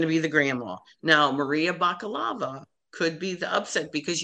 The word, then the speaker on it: Bakalova: So it's Maria Bakalova. That's how she pronounced her name the other night on Colbert.